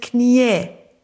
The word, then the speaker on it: Knie: Ist das einzige Wort im Deutschen, dass du, wenn du es in der Einzahl und in der Mehrzahl hast, gleich geschrieben wird, aber unterschiedlich ausgesprochen. Weil die Mehrzahl ist Knie. – Was? – Wenn du sagst, ein Knie, 2 Knie. – Nein, ich sag 2 Knie. – Nein, du sagst 2 Knie.